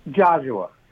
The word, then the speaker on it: Joshua: Is it Joshua? – Joshua. – Joshua. – Like Joshua, right. But Joshua.